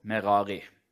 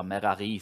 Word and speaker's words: Merari: Du har Kahat og Merari. Eller Merari, sikkert, hvis du skal ta det med hebraisk aksent.